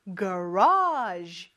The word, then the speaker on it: garage: 일단 이 단어에는 강세가 있는데 단어가 영어에는 이렇게 높낮이가 있어요. 우리는 차고 이렇게 얘기하지만 영어는 garage 이렇게 말을 하죠.